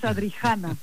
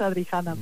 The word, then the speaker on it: Adrijana: Es en español es Adrijana, porque hay J entre medio, entonces sí que es Adrijana, pero bueno, está bien.